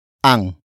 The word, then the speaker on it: ang: After that, we have ang. Ang. Ang. Ang is spelled as A-N-G.